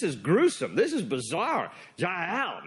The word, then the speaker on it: Jael: There's no J in the Hebrew alphabet. Her name is spelled and pronounced with a Y, Jael.